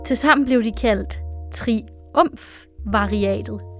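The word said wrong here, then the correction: triumviratet: Er det rigtigt udtalt, Emilie? – Triumviatet. – Triumviatet.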